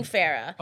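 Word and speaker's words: Phara'h: So, oh, like my name, P-O-L, with an accent. – Yes, exactly. So one R, so it's going to be P-H-A-R-A apostrophe H. Pronouncing Farrah. – Oh my God, that is so confusing. How is this child going to learn how to spell her name? – It's like Farrah.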